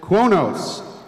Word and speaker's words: Qo'noS: The Klingon homeworld in Federation Standard is called Kronos, but if you ask your average Klingon, they call it Kronos. Can you spell it? – Kronos. Q-O-N-O-S.